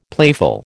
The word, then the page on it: playful: American English